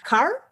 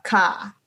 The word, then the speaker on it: car: Вот, например, они не произносят букву R после гласных, да, то есть американцы скажут слово «машина» car, а австралийцы и большинство британских акцентов и новозеландских скажут car. То есть без какого-то намека на R.